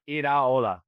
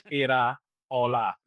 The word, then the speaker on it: Iraola: Iraola. Oh. The thing to remember in Spanish, with very, very few exceptions, every letter is pronounced. So it is Iraola.